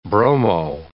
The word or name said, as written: bromo